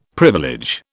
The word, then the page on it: privilege: British English